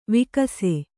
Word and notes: ವಿಕಾಸ: vikāsa; kannada

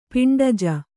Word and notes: ಪಿಂಡಜ: piṇḍaja

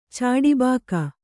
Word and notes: ಚಾಡಿಬಾಕ: Kannada

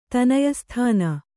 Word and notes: ತನಯಸ್ಥಾನ: Kannada

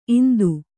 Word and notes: ಇಂತಿ: inti

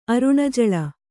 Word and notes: ಅರುಣಜಳ: Kannada

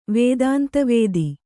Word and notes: ವೇದಾಂತವಾದ: Kannada

